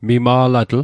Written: mima'latl

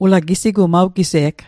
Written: gise'g

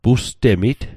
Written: pustemit

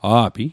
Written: a'pi

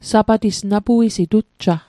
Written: Sapatis

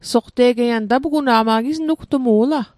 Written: soqte'get

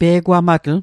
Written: pe'gwamatl

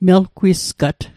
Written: melgwisgat

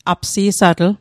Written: apsi'satl

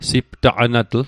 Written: siptaqanatl

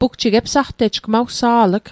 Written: epsaqtejg

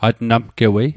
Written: atnamgewei

